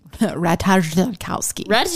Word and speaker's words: Ratajkowski: Ratajkowski. – Sorry, it is pronounced Emily Ratajkowski. Yeah.